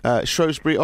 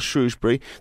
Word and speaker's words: Shrewsbury: The BBC says that the answer is both Shrewsbury or Shrewsbury. The traditional pronunciation of the oo in Shrewsbury only survives in the word Sue. And in earlier days, the town's name was spelled Shrewsbury, S-H-R-O-E-S-B-U-R-Y, or S-H-R-O-W-E-S-B-U-R-Y, with the ow pronounced as in show.